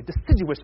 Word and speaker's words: deciduous: You see, a stump, the deciduous trees especially, I said it correctly, deciduous trees, the non-evergreen trees especially, can grow from a stump.